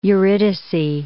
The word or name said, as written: Eurydice